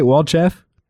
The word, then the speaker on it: Walchef: Did I say that right, Walchef? – You did.